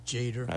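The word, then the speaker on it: Tjader: Ronnie Foster's Mystic Brew and Carl Jaders, is that how you pronounce his name? – I always thought it was Jader. – I says Jader. – Jader, yeah. Okay.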